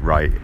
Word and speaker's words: write: And from rite, you get write. W R I T E. the, the W is silent.